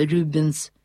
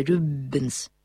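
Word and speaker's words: Rubens: Принятая по-русски форма имени Пауль заимствована с немецкого, так же, как и фамилия Рубенс, которая по-фламандски произносится Рюбенс, а на антверпенском диалекте Рюббенс.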